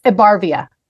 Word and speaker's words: Ebarvia: It's Ebarvia.